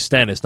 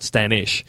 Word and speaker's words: Stannis: it's actually Stannis, not Stannish.